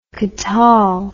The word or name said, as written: katal